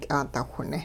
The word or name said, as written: ky'aadawhne